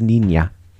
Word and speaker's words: Niña: So it's pronounced Niña. – Niña. Like Muñeño. – Niña is like little girl.